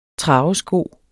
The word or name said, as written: travesko